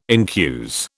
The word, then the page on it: incuse: British English